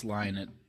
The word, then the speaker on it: Lynott: I think it's Lynott. – No, it's Lynott.